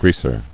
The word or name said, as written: Greaser